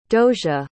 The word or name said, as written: dosia